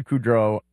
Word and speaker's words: Kudrow: So Lisa Kudrow, I think that's the way you're supposed to pronounce her name. – Kudrow? – Lisa, Kudrow. – Yeah. – Well, you're putting the emphasis on Kudrow.